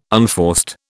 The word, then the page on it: unforced: British English